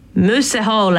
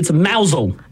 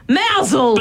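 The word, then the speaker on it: Mousehole: It's probably something like moose hole. – That's just offensive. – Or is it like moose hole? – Oh, it's actually mousel. – Mousel. – But it's spelt mouse hole. – It is spelt like mouse hole.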